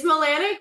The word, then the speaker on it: Milanak: It's Milanak, but that's okay.